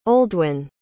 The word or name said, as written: Oldwyn